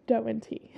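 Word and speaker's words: Do and Ti: It's spelled like Do and Ty, but it's pronounced Doe and T.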